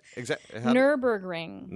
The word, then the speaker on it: Nürburgring: How do you say it? – Nürburgring.